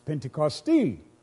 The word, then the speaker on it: Pentecost: Pentecosti. You put emphasis on the end of it.